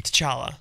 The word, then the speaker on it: T'Challa: But is it like challah bread? Like, do you not pronounce the CH, or is it like... – T'Challa. – T'Challa? – It's softer T. – Soft. – You went a little too hard on it.